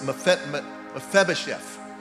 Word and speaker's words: Mephibosheth: Mesheba, uh, uh it's Mephibosheth. I almost said it right.